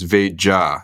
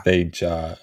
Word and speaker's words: Veja: Yeah, it's Veja. – Veja. Okay. – Because it's Portuguese and you'd pronounce the J.